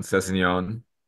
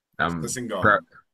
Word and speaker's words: Sessegnon: Nice pronunciation on Sessegnon. – Sessegnon.